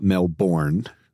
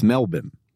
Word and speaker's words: Melbourne: It's not Melbourne. It's Melbourne. – Melbourne. – That's the way it's pronounced.